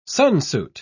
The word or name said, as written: sunsuit